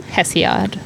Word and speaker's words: Hesiod: Hesiod.